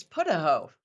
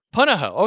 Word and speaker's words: Punahou: It's Punahou. – Punahou.